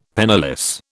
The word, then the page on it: penniless: British English